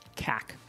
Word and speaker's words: CAC: It's often pronounced CAC.